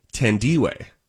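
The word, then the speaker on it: Thandiwe: So it's Tandy, Tandy, actually, actually, it's technically, these are always a little difficult for me, but the, the emphasis goes on the D. So it is Tandiwe.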